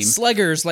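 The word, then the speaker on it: Slegers: Sledgers, like Slugger.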